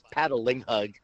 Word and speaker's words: Patalinghug: It's Patalinghug. It's just like it's spelled.